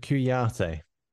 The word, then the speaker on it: Kouyaté: And Kouyaté.